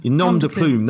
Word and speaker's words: nom de plume: Nom de Plume. – Nom de Plume, that's the one, Nom de Plume.